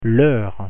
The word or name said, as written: heure